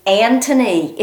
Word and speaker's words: Anthony: Well, Anthony Jennings, and no, I am not mispronouncing his name, that is Anthony. It has no H in it. – It's a very old English name. – Old English name, Anthony Jennings, J-E-N-N-I-N-G.